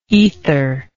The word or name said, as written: aether